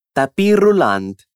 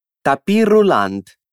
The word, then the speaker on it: tapis roulant: Mas como muitas das palavras estrangeiras que são emprestadas ao italiano, esse termo vai receber o nosso toque especial no momento de pronunciarmos. Tapis roulant. Diz você aí também. Tapis roulant. Tapis roulant.